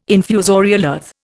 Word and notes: infusorial earth: Indian

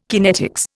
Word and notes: kinetics: Indian

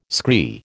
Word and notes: scree: American English

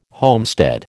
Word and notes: homestead: American English

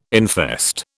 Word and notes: infest: British English

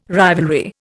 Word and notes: rivalry: Indian

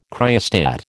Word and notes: cryostat: American English

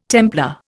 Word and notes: Templar: Indian

